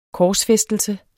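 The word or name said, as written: korsfæstelse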